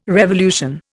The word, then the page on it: revolution: Indian